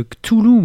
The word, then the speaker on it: Cthulhu: Ouais, et puis on va causer aussi pas mal le Cthulhu, moi je prononce comme ça. – Alors nous, moi c'est Cthulhu.